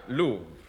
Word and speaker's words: Louvre: Stolen from the, how do you pronounce this? – Louvre. L-O-U-V-R-E, pronounced... – Louvre.